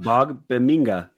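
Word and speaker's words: Ogbongbemiga: Ogbonbemiga. I still screwed that up. – Ogbonbemiga. Yeah. – Ogbonbemiga. Amen. Yeah. Okay. I spelled it out phonetically and I still screwed it up.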